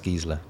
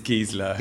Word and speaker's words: Gisela: And it's pronounced Gisela. – And it's Gisela. – Yeah, nailed it.